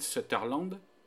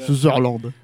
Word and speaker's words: Sutherland: qui est incarné par Donald Sutherland. Voilà, je l'ai bien prononcé. – T'as décidé de le prononcer correctement parce que sinon tu l'appelles comment ? – Sutherland. – Voilà. Sutherland. Ok, ouais. D'accord.